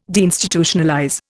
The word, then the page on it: deinstitutionalize: Indian